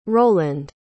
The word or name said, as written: roland